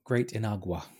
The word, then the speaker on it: Great Inagua: Great Iguana or Great Iguana? – Oh, Great Inagua. – Inagua, the Great Inagua.